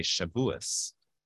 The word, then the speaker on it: Shavuot: She said, we say Shavuos. She says, I know it ends with a T, but we always say Shavuos.